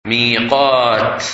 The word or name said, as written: Miqat